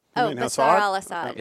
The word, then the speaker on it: Bashar al-Assad: Bashar al-Assad.